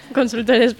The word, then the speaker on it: Consultores: También nos acompaña, es un placer que repita, abogada del Buffet FB Consulters, lo he dicho bien. – Consultores, pero sí, perfecto. – Vale, vale. Para unos que tienen el nombre en castellano, yo lo pongo en inglés.